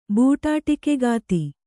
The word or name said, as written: ಬೂಟಾಟಿಕೆಗಾತಿ